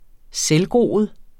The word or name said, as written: selvgroet